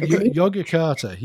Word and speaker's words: Yogyakarta: Yogyakarta principles. Am I pronouncing that correct? – Oh, yeah. Yogyakarta.